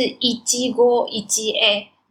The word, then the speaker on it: 一期一会: 一期一会的日语发音是一季过一季 A，就是我对于缘分，我们相遇了之后的这个缘分当成是当下可唯一的这一次去面对。